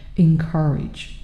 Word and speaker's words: encourage: 那我们美音呢？变一下 encourage，er 啊，不要读成 encourage，我们是。 encourage，encourage，这个 r 的一个音一定要体现出来。 encourage 啊，最最经常犯的一个错误啊，也是给我这边学生讲课一直提到的，就是我们这个 w o r r y，怎么来说呢？